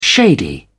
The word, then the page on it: shady: British English